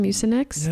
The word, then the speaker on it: Mucinex: Mucinex? – Yes, you did.